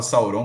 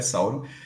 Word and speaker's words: Sauron: E é Sauron, eu falava Sauron, mas a pronúncia na série é Sauron.